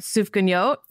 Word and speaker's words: Sufganiyot: Sufganiyot? – Yeah.